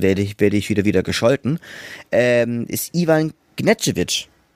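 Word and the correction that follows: Gerčević: Doch, Ivan, Ivan Gercevic von, von Bayreuth, der natürlich dort unglaublich viele Vorlagen gegeben hat, ich glaube 18 oder so.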